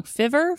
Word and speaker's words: Fiverr: Fiver, fiver. It ri- looks like it rhymes with river with an F, and then there's two R's at the end.